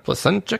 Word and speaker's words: Placentia: Placentia, California.